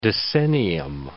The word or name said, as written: decennium